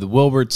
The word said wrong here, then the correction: Wilbur: Soot. I just said his name wrong. Wilbur Soot.